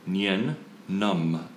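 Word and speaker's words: Nien Nunb: Nien Numb. N-U-N-B.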